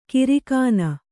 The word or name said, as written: ಕಾರಕೂನ